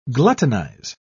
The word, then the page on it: gluttonize: British English